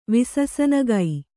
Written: ವಿಸಸನಗೈ

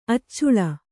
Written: ಅಚಲೆ

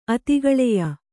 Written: ಅತಿಗಳೆಯ

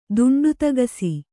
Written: ದುಂಡುತಗಸಿ

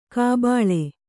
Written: ಕಾಬಳ್ಳಿ

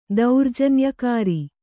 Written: ದೌರ್ಜನ್ಯಕಾರಿ

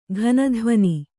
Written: ಘನಧ್ವನಿ

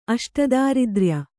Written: ಅಷ್ಟದಾರಿದ್ರ್ಯ

